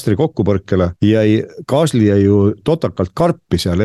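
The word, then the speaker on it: Gasly: Kasli või Kasli, nagu prantsuspäraselt hääldada, oli üheteistkümnes, selle koha pealt väga hea tulemus.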